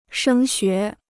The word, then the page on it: 升学: shēng xué